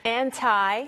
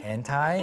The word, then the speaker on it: anti: Anti는 물론 두 가지 발음이 있죠. – Anti. – Anti. – Anti. – Anti. 편하신 대로 발음하시면 되겠고 mm-hmm.